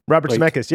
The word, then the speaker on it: Robert Zemeckis: It's Robert Zemeckis. – Yeah, it's his first movie, his very first movie as director. – Am I pronouncing that right? – Robert Zemeckis, yeah.